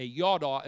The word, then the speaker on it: yada: It's Y-A-D-A. Y-A-D-A, it's yada, yada. Like if somebody were to say yada, yada, yada, that's spelled the same way, yada, yada.